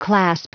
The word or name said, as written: clasp